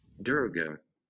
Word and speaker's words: Durga: Durga. – Get out of here, bro. – I was right. Durga.